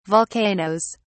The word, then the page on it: volcanoes: British English